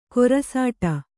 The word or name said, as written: ಕೊರಸಾಟ